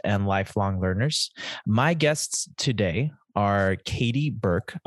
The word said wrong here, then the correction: Bork: Did I pronounce it correctly? – Bork. – My apologies. Katie Bork and Wesley, can you pronounce your last name for me? – Belanca.